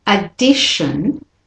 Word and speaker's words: addition: So addition, the at is said, the it is said, and the shin is the weak syllable.